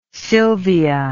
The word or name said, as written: sylvia